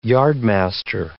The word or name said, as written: yardmaster